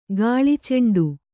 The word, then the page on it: ಗಾಳಿಚೆಂಡು: kannada